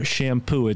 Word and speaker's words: Champoux: Peter Champoux. It's spelled C-H-A-M-P-O-U-X.